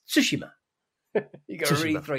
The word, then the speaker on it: Tsushima: Someone asking how to pronounce Tsushima, and it's kind of like Tsushima. Right, there's a subtle difference between Tsushima and Tsushima. Just enunciate the T. Tsushima, and that's the key.